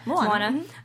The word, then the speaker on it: Moana: Yeah, Moana.